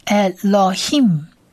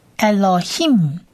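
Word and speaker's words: Elohim (אֱלֹהִים): 而大家应该都记得这个最后一个字母像口一样，中文的口一样的字，它其实是希伯来文字母 man 的字尾型，所以它发音是发嘴巴闭起来的的音，所以这整个字合起来念就是 Elohim Elohim。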